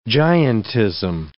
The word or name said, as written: giantism